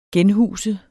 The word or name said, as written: genhuse